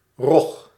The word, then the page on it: skate: /skeɪt/